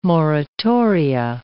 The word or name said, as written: moratoria